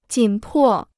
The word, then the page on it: 紧迫: jǐn pò